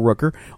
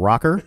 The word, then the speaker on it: Rooker: Michael Rooker, or if you're Joe, Michael Rocker. How did you say it? – I said Rooker.